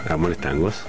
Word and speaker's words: Amores Tangos: Amores Tangos. – Pronúncienme bien la S al final.